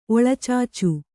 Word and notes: ಒಳಚಾಚು: oḷacācu

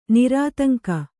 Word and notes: ನಿರಾತಂಕ: nirātaŋka

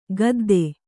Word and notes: ಖುದ್ದ: Kannada

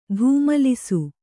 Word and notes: ಧೂಮಲಿಸು: Kannada

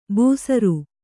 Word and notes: ಬೂಸರು: Kannada

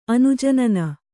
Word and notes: ಅನುಜನನ: anujanana

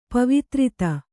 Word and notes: ಪವಿತ್ರತೆ: Kannada

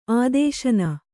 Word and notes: ಆದೇಶನ: ādēśana

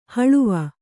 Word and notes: ಹೊಳವು: Kannada

